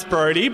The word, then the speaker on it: Brodi: As you just heard, my name's Brody, but it's spelled a bit differently than normal. It's just B-R-O-D-I.